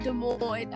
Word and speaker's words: Des Moines: I know how to say Des Moines, Iowa. – The S is silent. Des Moines, Iowa.